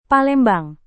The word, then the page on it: palembang: Indonesian